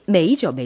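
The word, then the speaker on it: 메이 (梅): 이 매화가 발음이 메이죠, 메이. – 네. – 아, 이것도 잘 모르겠네요. 메이 하면은 따오메이 할 때 메이 아닌가요, 혹시? 따오메이. 발음이 똑같죠? – 네, 발음도 똑같습니다.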